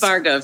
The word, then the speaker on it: Bargo: It's Bargo. The A is silent at the end.